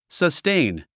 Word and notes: sustain: səstéin; サステイン

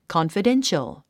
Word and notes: confidential: kɑ`nfədénʃəl; カンファデンシャル

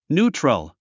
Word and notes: neutral: n j úːtrəl; ニュートゥラル

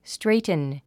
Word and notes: straighten: stréitn; ストレイトゥン

